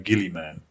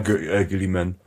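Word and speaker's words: Guilliman: Ich dachte früher mal, das heißt Guillaume. – Es wird Guillaume ausgesprochen. Ja, aber wenn du dir die Hörbücher anhörst, Gilliman.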